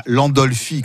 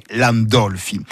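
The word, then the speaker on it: Landolfi: il y a l'accent tonique, c'est pas l'Andolfi comme Monsieur Brun, mais l'Andolfi. Alors, l'Andolfi avec un L et pas Gandolfi avec un G, comme on l'entend parfois.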